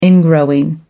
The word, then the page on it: ingrowing: American English